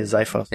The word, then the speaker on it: Xiphos: How do we pronounce that X again? – Yeah. I don't know. It's X-I-P-H-O-S. – Yeah. – I would say Xiphos. – Yeah, Xerxes is the person, so Xiphos. Yeah, that makes sense.